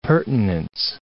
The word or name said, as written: pertinence